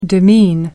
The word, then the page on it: demean: American English